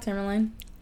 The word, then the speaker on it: tourmaline: Yeah, black tourmaline.